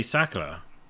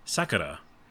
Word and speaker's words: Sakura: I can't get past the pronunciation because I just heard it in the anime that's in the game, and I think they say Sakura. Sakura. Yeah, like that. But you could, you know, like butcher it like I do, and I say Sakura Wars, which is probably fucked.